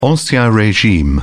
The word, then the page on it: ancien regime: British English